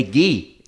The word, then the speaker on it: Guy: And actually, Aaron doesn't say like Guy French. He says Guy like annoying, like, hey, Guy. Like, it's like more of like a North Country hick sound than actually like a French pronunciation.